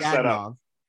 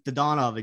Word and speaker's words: Dadonov: Because when he was here in South Florida, it was always Evgeny Dadonov. – Yeah. – Now he goes out west and all of a sudden it's Dadonov again.